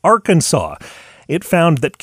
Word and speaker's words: Arkansas: They added an R to Akansa and then, because it referred to a group of people, they added an S at the end, which in French you wouldn't pronounce anyway. And that's roughly where the modern spelling of Arkansas came from,